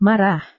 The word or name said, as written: marah